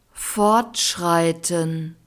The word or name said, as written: fortschreiten